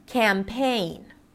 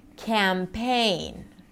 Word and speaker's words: campaign: Now let's talk about trickier words where you have two syllables and a primary stress and a secondary stress. No reductions here. Campaign. Campaign. So here to get to the A sound in campaign, you have to allow some space, right?